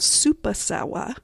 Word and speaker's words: Supasawa: And then I used a little dash of a thing that's called supasawa. I'm saying it how it's spelled, S-U-P-A-S-A-W-A.